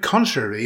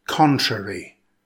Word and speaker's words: contrary: Now, the word contrary is an adjective. It consists of three syllables. Stress the first syllable. It's pronounced contrary. Contrary.